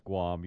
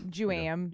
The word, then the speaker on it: Guam: All right, once again, if you have a friend, remind them that it's pronounced Guam. You don't want them to... – Juam.